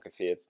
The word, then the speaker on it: Kvyat: Like Fiat the car? – No. – Kvyat? – Kvyat. Kvyat. That's how you pronounce it.